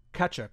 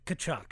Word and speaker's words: Tkachuk: a glorious slap shot by Matthew Tkachuk. Am I pronouncing that? – Tkachuk.